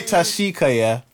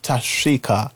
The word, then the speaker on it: Tashika: You know, you guys say Tashika, but it's actually pronounced Tashika. It's a not that you said you did a little shh.